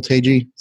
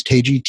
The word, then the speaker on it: Tagye: LinkedIn, Will Tagye. So if you, there's not many of us. So if you Google it. – Spell that. – Last name is Tagye, T-A-G-Y-E, Tango, Alpha, Golf, Yankee, Echo.